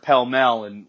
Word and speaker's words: Pall Mall: I mean, you pronounce that word pell mell when you read Shakespeare, but it's spelled Pall Mall.